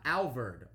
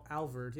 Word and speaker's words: Alvord: And this one comes to us from it's from Alvira, Texas. Is that Alvord, Texas? Am I saying this right? – Yeah, it's North Texas town. Alvord, yeah, AF.